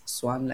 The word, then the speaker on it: Xuan: I would just introduce myself as Swan like a swan.